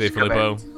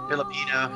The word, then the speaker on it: DeFilippo: He was DeFilippo. – Yeah. Filipino.